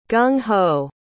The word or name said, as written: gung-ho